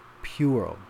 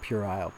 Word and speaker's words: puerile: Pronounce pural, pural. Pure as in pure. Oh, not puerile, pural, pural.